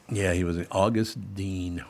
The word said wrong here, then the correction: Dane: or Dane is the way he pronounced it.